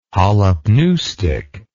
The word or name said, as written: holopneustic